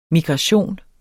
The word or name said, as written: migration